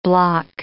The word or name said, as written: bloc